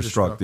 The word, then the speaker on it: Strzok: Struck. Yeah, no, Peter Strzok, the ex-FBI agent, said, Americans should absolutely trust FBI after the Trump raid.